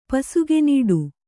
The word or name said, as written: ಪಸುಗೆನೀಡು